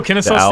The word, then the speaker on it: Kennesaw: Kennesaw State. – Oh, Kennesaw State.